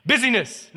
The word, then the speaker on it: busyness: Busyness, not business, but busyness.